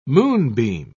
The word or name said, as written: moonbeam